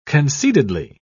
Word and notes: concededly: British English

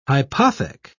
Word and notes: hypothec: British English

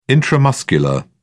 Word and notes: intramuscular: British English

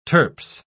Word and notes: turps: British English

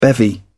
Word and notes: bevy: British English

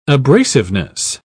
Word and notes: abrasiveness: British English

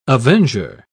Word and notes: avenger: British English

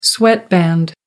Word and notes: sweatband: American English

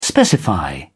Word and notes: specify: British English

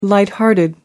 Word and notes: light-hearted: American English

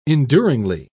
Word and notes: enduringly: British English

